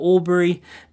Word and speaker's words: Albury: He's out of Albury, and that's how you say it, isn't it? – Yeah.